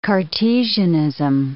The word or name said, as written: Cartesianism